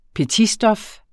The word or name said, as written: petitstof